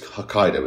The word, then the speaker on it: Hokkaido: So this is a place, Hokkaido, is that right? – Yeah. – Do we know where in Japan Hokkaido is? – So...